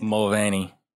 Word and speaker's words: Mulvaney: Dylan Mulvaney. Is that how you say it? – Mulvaney.